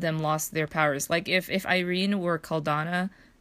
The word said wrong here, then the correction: Cardala: Am I saying her name right? – Cardala. – Cardala, thank you.